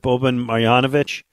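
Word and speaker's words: Boban Marjanović: I love, I'm going to say the name right, Boban Marjanovic.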